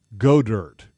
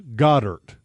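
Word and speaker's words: Goedert: But the guy that I really like is Dallas, and I like to call him Godert. JP says his name is pronounced Goddard. – Yeah. – Is that right? – That's right.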